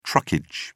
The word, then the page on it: truckage: British English